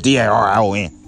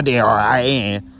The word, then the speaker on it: Darion: So my name is Darion, not Darian, because I used to spell my name with an A.